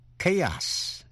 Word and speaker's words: chaos: Sau đây có những chữ mà âm CH đọc là K, như trong ba chữ chaos, C-H-A-O-S.